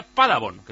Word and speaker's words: Paderborn: Enrique nos cuenta que el Paderborn se pronuncia Padabon,